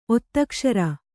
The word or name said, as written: ಒತ್ತಕ್ಷರ